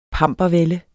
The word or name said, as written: pampervælde